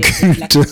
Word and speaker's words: culte: Et la soirée culte. – Il faut bien prononcer soirée culte. Hein. – Pourquoi ? – Ben, il faut bien prononcer le T à la oui.